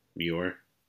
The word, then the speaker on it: Muir: Muir.